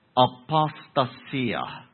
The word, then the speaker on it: apostasia: And when you put it in the form that you find it in this verse, it's pronounced apostasia. You hear apostasy in it.